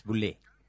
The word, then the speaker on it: Boulle: Está, porque yo creo que Pierre Boulle se pronuncia este señor. – Boulle. – Boulle. Creo que se pronuncia Boulle. – Boulle. – ¿Le pegué? Boulle. Perfecto. Es la única que voy a pegar. – Es Boulle.